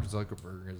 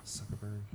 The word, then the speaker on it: Zuckerberg: Like, you know, first of all, Mark Zuckerberg is this. Is that how you say his name? – Yeah. – Zuckerberg.